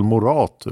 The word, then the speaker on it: Morath: Inte lätt med uttalet. Men för oss som är med på den tiden och hörde namnet ska den sista stavelsen uttalas så att det rimmar med lat. Axel Morath utan H. Med betoning på A.